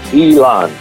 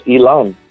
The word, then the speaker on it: Ilan: Perfectly correct. Ilan. That's a Hebrew name, and it means a big tree in Hebrew. And you pronounce it just right, Ilan.